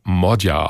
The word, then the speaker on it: Magyar: Er schreibt sich zwar Peter Magyar, also mit Y, spricht sich aber Modja aus.